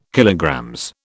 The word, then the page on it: KG: British English